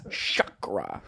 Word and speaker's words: chakra: How do you say, chakra or chakra? – I would say chakra. – See, I said that too. I was corrected the other day. It's chakra. – Who corrected you? – One of the assistant directors on my little thing. They were like, you've been saying it wrong.